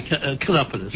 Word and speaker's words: chiropodist: I pronounce it hard, see, chiropodist.